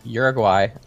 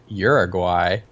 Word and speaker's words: Uruguay: Starting things right off, Uruguay, or how people in the United States pronounce it, Uruguay. – You just said Uruguay it? Twice. – Shoot. I meant Uruguay. Is that actual Spanish name, Uruguay? And it's Uruguay for English speakers.